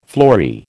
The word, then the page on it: flory: American English